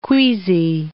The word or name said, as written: queasy